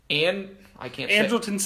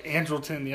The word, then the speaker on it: Andrelton: Andrelton Simmons. – I can't say. – Andrelton, yep.